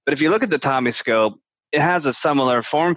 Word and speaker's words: TOMi Scope: But the TOMi Scope, am I saying that correctly? – That's right.